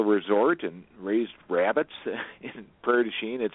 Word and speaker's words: Prairie du Chien: Let's do the soldier from, what is it, Pierre Duchamp? – Oh, Prairie du Chien is how they pronounce it locally.